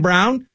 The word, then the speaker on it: Braun: But Christian Brown, which by the way, I would never pronounce Braun Brown, but it's Christian Brown.